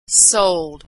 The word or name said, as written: sold